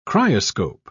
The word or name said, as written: cryoscope